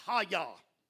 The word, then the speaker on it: hayah: That verb is hayah. It is spelled H-A-Y-A-H, Haya.